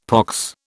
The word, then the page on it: pox: British English